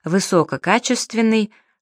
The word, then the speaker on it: высококачественный: В таких словах следует ставить два ударения. Дополнительное — на втором слоге первой части, основное — на второй части сложного слова. Высококачественный, широкомасштабный.